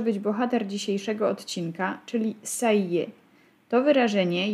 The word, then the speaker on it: ça y est: Pierwsza trudność to wymowa, bo wymawiamy go tak, jakby to były dwa wyrazy, a nie trzy. Ça y est. Zaimek i przykleja się w wymowie do czasownika être.